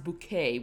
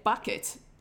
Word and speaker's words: Bucket: But her surname, the couple surname is supposed to be pronounced bouquet, which is always emphasized. – Bouquet. – But it's spelt bucket. – Oh. – So every time someone reads their surname to her, they say bucket, and then she turns around, no, it's bouquet.